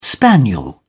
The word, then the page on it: spaniel: British English